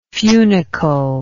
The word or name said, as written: funicle